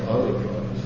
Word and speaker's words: Omicron: It's not Omicron, because if it was Omicron, you would have an Omega there. Omega, that would be Omega, Omicron. So Omicron is how you pronounce it.